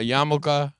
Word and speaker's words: yarmulke: A yarmulke. – Yarmulke. – Pronounce the hard L.